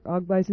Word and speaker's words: Agbai: Incidentally, for those of you who need to know, Dr. Agbai's name is spelled A-G-B-A-I, A-G-B-A-I, pronounced Agbai.